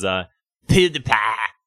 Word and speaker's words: PewDiePie: His name, I believe, the way you pronounce his name is PewDiePie.